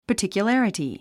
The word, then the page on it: particularity: pərtìkjulǽrəti; パティキュララティ